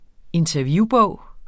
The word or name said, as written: interviewbog